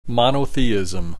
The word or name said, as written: monotheisim